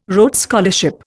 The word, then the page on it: Rhodes Scholarship: Indian